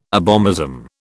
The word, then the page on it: abomasum: British English